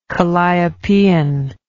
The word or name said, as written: calliopean